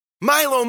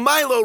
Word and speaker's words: Milo: "Milo, Milo, rhymes with Fido!"